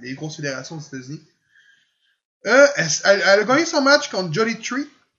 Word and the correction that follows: Jody Threat: si je le dis bien. – Jody Threat.